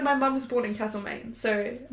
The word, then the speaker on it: Castlemaine: My mum was born in Castle, Maine. – It's cold. – No, but that town is pronounced Castlemaine. The people who live there pronounce it Castlemaine.